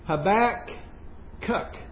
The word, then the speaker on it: Habakkuk: And I'll tell you what, somebody pronounced that the right way, right? Habakkuk.